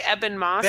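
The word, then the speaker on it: Ebon Moss: I'm so sorry that I'm not getting his name correct, but yes, the guy who plays Evan Moss. – Bill. Say his name again. – The last name, like, Eben Moss. – Ben Eben Moss. Thank you.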